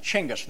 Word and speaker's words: Chinggis: In America, you say Genghis Khan, but we pronounce it Chinggis. That G is actually pronounced like a CH, Chinggis Khan. Okay.